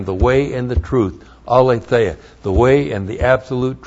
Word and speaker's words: aletheia: Aletheia. A-L long E-T-H-E A-L-E-T-H-E-I-A.